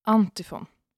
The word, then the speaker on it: Antifon: För jag ska prata om antifon.